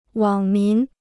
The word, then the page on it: 网民: wǎng mín